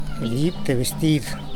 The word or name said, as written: dib te bestid